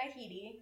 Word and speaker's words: Headey: Oh, Lena Headley. Lena Headley? – Yeah. – Yeah. – Cersei. – Or Heedy. Sorry. – Heedy. No. Sorry. – Lena Headey.